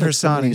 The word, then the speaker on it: Harsanyi: How do you say this guy's name? – It's David Harsanyi.